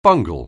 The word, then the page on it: bungle: anglais